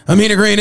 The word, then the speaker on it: Ameena Green: Ameena Green is in the building.